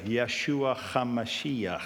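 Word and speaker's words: Yeshua HaMashiach (ישוע המשיח): Yeshua HaMashiach. That's how they say it in Hebrew.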